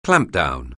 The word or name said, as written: clampdown